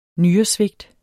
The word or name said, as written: nyresvigt